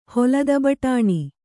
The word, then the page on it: ಹೊಲದಬಟಾಣಿ: kannada